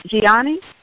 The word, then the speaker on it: Jiyane: And your name is pronounced Jiyane? – Yeah, Shimmy Jiyane.